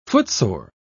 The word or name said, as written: footsore